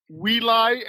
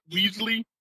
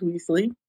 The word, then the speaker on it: Weasley: Can someone explain to me why my text reader insists on pronouncing it Weely and not Weasley? – No, I have no idea. Mine does Weasley. – Kind of weird. – Mine does Weely. Every time. As if the S doesn't exist.